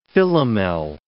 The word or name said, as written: Philomel